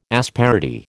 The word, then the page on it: asperity: American English